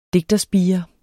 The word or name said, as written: digterspire